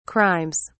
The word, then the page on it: crimes: British English